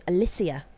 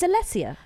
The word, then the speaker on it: Alessia: Although it did kind of wind me up slightly that she kept being called Alicia. – Nice. – It's Alessia, right? Are we just pronouncing it wrong? – I don't think so.